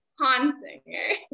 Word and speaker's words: Honsinger: Honsinger.